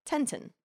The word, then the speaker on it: Tintin: In French, Tintin is pronounced more like Tintin. – Ah, just a little fact there. – Tent instead of tint.